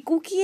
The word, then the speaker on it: Cuky: Así que yo he sido toda la vida Cookie. El problema de Cookie es que en Estados Unidos eh, la gente cree que significa galleta, pero la verdad que se escribe C-U-K-Y.